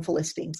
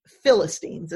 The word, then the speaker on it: Philistines: I was raised to call them Philistines. It's Philistines is how you say it.